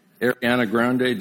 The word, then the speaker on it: Ariana Grande: It was Ariana Grande. Am I saying that right, young people? Ariana Grande. Did I get it right? Thank you.